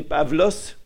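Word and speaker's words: Pavlos (Παύλος): À partir de ce moment-là, oui, il est dans la ville de Paphos, où il va rencontrer Sergius Paulus ou Sergius Paulos, ou je ne sais pas comment on l'a dit, parce qu'on ne sait pas exactement comment prononcer. Les Grecs disent que c'est Pavlos, d'où ça vient toutes ces appellations de Pavel dans le monde slave.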